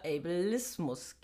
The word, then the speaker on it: Ableismus: Ähm, Habe ich es jetzt richtig ausgesprochen? Ja. Ja. Ableismus. Ableismus. Also wir werden das Nein. noch üben. Ableismus. Äh, Und wenn, ähm, es ist an Englischen angelehnt, deswegen äh, hast, hat das so ein bisschen Zungenbrecher-Fähigkeiten.